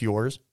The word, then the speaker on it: URS: With that being said, I actually invested in a Project URS, pronounced yours.